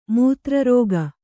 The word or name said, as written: ಮೂತ್ರರೋಗ